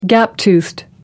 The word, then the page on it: gap-toothed: American English